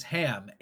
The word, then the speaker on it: HAAM: The cause this week is HAM, H-A-A-M, pronounced like the pork product.